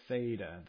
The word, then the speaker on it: theta: That's a theta, the O that's got the horizontal bar is the letter in Greek that is pronounced with a TH, fellow.